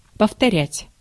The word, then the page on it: повторять: /pəftɐˈrʲætʲ/; povtorjat